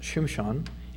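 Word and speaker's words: Shimshon (שמשון): In Judges 13, verses 24 and 25, we read, so the woman bore a son and called his name Samson, which is pronounced Shimshon, and means sunlight in Hebrew.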